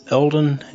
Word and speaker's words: Eldon: My name is Eldon, and as usual, Lol is here. – Do you spell that E-L-D-O-N? – On the podcast.